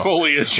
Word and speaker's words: foliage: When that foliage and all the when that forbidden forest foliage. – What did I say? – You put the wrong emphasis on the wrong syllable.